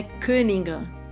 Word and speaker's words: Koninger: Oi, e primeira coisa eu vou te pedir para me corrigir a pronúncia do seu sobrenome. Fala como você falaria, se apresentaria para um alemão. – Meu nome é Koninger.